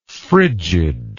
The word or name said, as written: frigidly